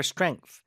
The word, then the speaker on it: strength: We need time to grow back our strength. We need time, 시간이 필요하다, to grow back our strength. To grow back our strength. Back에 힘 들어가죠. To grow back our strength. Strength. TH 발음 마무리 잘해주시고.